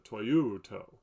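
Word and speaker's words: toyuto (τοιοῦτο): With a little inflection mark, you'd pronounce it toyuto.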